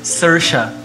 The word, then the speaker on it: Saoirse: Um momento aqui falar a pronúncia correta do nome. Saoirse. Saoirse. – S-A-O-I-E.